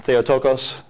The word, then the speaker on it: Theotokos: Now, in modern Greek, you would use long O's there. Theotokos, right? – Theotokos.